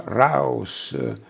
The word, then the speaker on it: Raus: La si usava come conta per escludere uno dietro l'altro quel raos, corrisponde alla pronuncia Raus, dell'omonimo lemma tedesco usato come ordine, Raus, fuori.